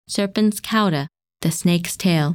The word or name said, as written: Serpens Cauda